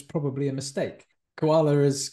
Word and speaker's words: koala: So instead of gula, it became guala, and finally adopting the word koala.